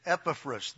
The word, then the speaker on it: Epaphras: And this guy, Epaphras, and it's Epaphras, the emphasis is on the first syllable, as in Septuagint.